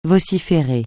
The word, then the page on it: vociférer: francais